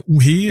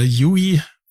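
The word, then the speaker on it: u-he: UHE, genau. würde ich sagen. – Oder UI, wenn es die Amerikaner aussprechen würden.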